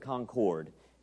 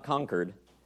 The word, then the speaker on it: Concord: We do pronounce it Concord. It's not Concord, like, you know, up here in New England.